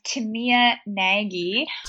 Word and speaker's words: Timea Nagy: Yeah, so Timea, I think her name is pronounced, well, I know her name is pronounced Timea Nagy. I always pronounced it Timea for the longest time. And then I met a whole bunch of her family and friends and everyone was calling her Timea. And I said, Timea, I've been saying your name wrong this whole time. And she said, oh, I love how you say my name. continuing on saying as Timea. Most people say Timea. It's a Hungarian name.